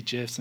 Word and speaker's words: GIFs: GIFs and colors and all this shit going on. – Oh, you pronounce it with a J.